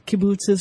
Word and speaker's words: kibbutz: And all of the Jews from Europe started showing up to settle there, and they did have these kibbutzes or kibbutz.